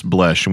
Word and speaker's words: Blesch: Do you remember last week, we had an email from a guy named Curtis Blesch, and we made fun of his name because he said it rhymed with flesh? – Curtis Blesch.